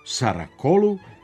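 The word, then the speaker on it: Saracoğlu: u čijem se nazivu također nalazi to muklo G, naši sportski komentatori izgovaraju sa svim mogućim kombinacijama glasova. Sukru Saracoglu, Šukru Saracoglu, Šukru Sarakolu i Šukru Saracoglu.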